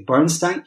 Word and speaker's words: Leonard Bernstein: E o Leonard Bernstein, pronuncia-se, no caso dele, Bernstein, era conhecido como o Bernstein do Leste.